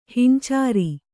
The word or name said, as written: ಹಿಂಚಾರಿ